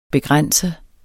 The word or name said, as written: begrænset